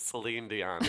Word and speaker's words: Celine Dion: And now it's by the way, it's Celine Dion. Is it that hard to pronounce? Celine. As though that's the crazy name. It was just Celine Dion.